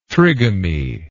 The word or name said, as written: trigamy